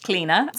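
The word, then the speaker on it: Clíodhna: So it is C-L-I-O-D-H-N-A, mm-hmm. – if you're looking her up. Pronounced Clina.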